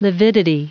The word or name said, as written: lividity